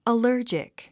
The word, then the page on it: allergic: ələ́ːrdʒik; アラージク